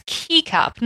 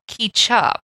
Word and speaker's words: kecap: it's literally spelled K E C A P. It's Keycup, not Keychup.